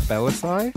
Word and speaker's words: Bellassai: You can find me on Twitter and Tumblr and Facebook / Matt Bellassai. M-A-T-T. No, I'm not going to spell it my own name. Bell Ass AI. – Spelled how it sounds.